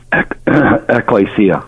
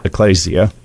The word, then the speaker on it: ecclesia: Did I pronounce that right? – Ecclesia. – Ecclesia. Thank you.